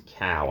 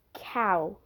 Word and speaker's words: Kao: I think it's Cow, I think is how you pronounce that last name. K-A-O. – Alex Cow may have been best known for his role as Archie Johnson in the hit television series CSI.